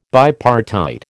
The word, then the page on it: bipartite: American English